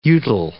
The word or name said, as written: utile